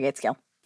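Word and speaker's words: Gaitskill: Is that how you say it? Mary Gateskill? – Yeah, Mary Gateskill.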